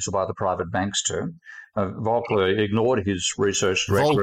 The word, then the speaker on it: Volcker: That was the somebody told me I was pronouncing his name wrong. It's Volcker. Mm. Is that correct? – Yeah.